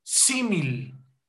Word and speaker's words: símil: Y me estoy refiriendo al símil. Símil. ¿Men? Lleva acento en la primera I. Símil. Esta figura retórica, hermanos, significa semejante o parecido a otro.